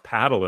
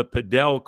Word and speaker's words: Padel: It's P-A-D-E-L. It kind of looks like it could be pronounced paddle, and I thought it was until I looked into it, and it's the Pro Padel League, and they have their first team invested in L.A.